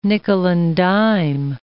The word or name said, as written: nickel-and-dime